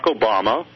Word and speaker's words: Obama: And, of course, there's Barack Obama, rhymes with Osama, with the middle name Hussein.